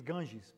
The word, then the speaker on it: Ganges: Ganges. Okay, is that how you say it? Because I know in Portuguese, Ganges, but that doesn't sound right in English, does it?